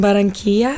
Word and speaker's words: Barranquilla: So you were born in Barranquilla. Mm-hmm. Am I saying that correctly? Mm-hmm. Or somewhat correctly? – Yep.